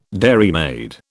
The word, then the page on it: dairymaid: British English